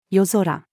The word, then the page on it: 夜空: female